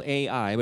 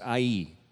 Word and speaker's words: Ai: The next city after Jericho that they need to conquer is a city spelled Ai, but it's pronounced Ai.